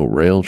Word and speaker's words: Roehl: Well, it looks like for the fourth year in a row, Rail Transport, which is R-O-E-H-L, they pronounce it rail, won the safest, the prestigious, or what is it?